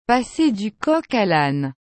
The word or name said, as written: passer du coq a lane